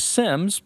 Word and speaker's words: Seims: And just as a mention for everybody, of course, we'll have this in the show notes, but Tim's last name is Sims, but it's spelled S-E-I-M-S.